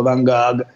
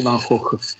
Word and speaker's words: Van Gogh: Mi hanno detto che sì, Van Gogh, mi hanno detto che si pronuncia così, mi hanno cazziato perché dicevo Van Gogh e invece Van Gogh.